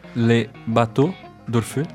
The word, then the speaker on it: Le Bateau d'Orfeu: Como, é, como é a pronúncia em francês? Le Bateau d'Orfeu.